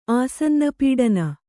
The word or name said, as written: ಆಸನ್ನಪೀಡನ